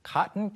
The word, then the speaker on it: cotton: 발음이 이제 우리가 그 면 또는 솜을 가리킬 때 코튼이라고 외래어처럼 표기하는 경우도 있는데 발음이 이제 cotton, T 발음이 안 날 때가 많죠. 특히 미국 영어에서.